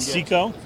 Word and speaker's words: Cicco: I believe this is going to be pronounced Cico, C-I-C-C-O. Let's see Nat Cico, yeah.